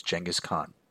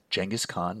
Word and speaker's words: Genghis Khan: That was Genghis Khan. Now, I know some of you are sitting there going, is he saying Genghis? Genghis Khan, not Genghis? And yes, I'm saying Genghis Khan. Either pronunciation is acceptable.